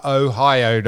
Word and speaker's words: Ohio: I'm still staggered the fact you couldn't pronounce Ohio. Ohio. O-H, you said. H turned me off as soon as you said H. Ohio, Don.